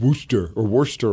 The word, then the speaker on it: Worcester: As opposed to, I see a sign that says Worcester, and like, no, you idiot, it's Worcester or Munster.